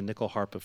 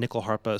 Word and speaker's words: nyckelharpa: bit to the side as I play the nickel harpa for most of my professional career at this point.